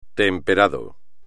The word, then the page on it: temperado: espagnol